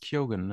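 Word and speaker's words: Keoghan: Barry Cogan? Cogan? Ich kann den Namen nicht aussprechen. – Na, der den Joker hier spielt, auf jeden Fall. – Ja, der, der, sagt, der, der heißt Kyogen, ne? – Kyogen? – Ja.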